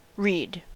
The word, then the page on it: reed: American English